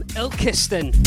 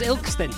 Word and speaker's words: Ilkeston: Scattergood from Ilkeston. I've been saying it wrong all these weeks. Oh, it's Ilkeston.